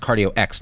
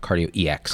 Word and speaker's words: Cardio X: So it's pronounced Cardio X, not Cardio EX. – Correct. – Got it. And like X like expert.